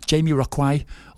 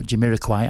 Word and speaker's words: Jamiroquai: Yes, Jamie Rockwai, or Jamiroquai as is correctly pronounced, and Earth, Wind and Fire.